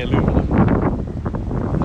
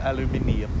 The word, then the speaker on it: aluminium: I say aluminum. Instead of aluminium.